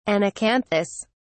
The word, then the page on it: anacanthous: British English